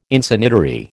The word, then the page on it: Insanitary: American English